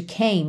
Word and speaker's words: came: Porque a letra M de came não é a última letra da palavra, certo? A gente tem C-A-M-E. Só que o E não é pronunciado.